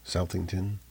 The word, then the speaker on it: Southington: Southington.